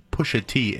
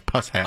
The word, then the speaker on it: Pusha T: Just for anybody that is in the know, Alan pronounces Pusha T as Puss Hat.